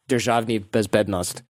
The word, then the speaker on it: Državni Bezbednost: It used to be called state security, like Državni Bezbednost is how we would pronounce that.